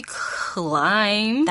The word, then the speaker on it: climb: It must be climb. – That's right. Now, how do we spell climb? – Well, C-L-I-M, and don't forget B. – That's right. Even though we don't really have to pronounce it. It still spells C-L-I-M-B, climb.